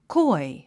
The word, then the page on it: coy: kɔ́i; コォイ